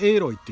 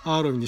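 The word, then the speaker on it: Aloy: プレイステーション、まあプレイステーション 3 のドラゴンズドグマも面白かったですけれども、あれ以上になんかハマりましたね。すごい、主人公のキャラクターエイロイちゃん、日本語ではアーロイって書いてますけど、発音は英語の発音はエイロイっていう、多分とはそのままカタカナでエイロイって書くとエロイっていう風に見えるからアーロイにしたのかなって気はしますけれども、主人公の女の子、実はオランダの女優さんがモデルらしいんですね。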